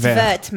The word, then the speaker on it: vert: You don't pronounce the T first. It's like just like it's vert, mate.